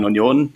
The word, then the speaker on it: Union: Und es gab ganz viele Varianten, Union Berlin. Und ich dachte, okay, nee, eigentlich heißt der Verein Union. Jeder Unioner sagt auch Union und auch auf Englisch kann man auch Union sagen, ohne Probleme, man muss nur wissen, dass es Union heißt.